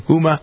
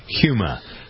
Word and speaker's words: Huma: Is that how you pronounce her name? Huma or Huma? How do you pronounce her name? I think it's Huma. – Huma.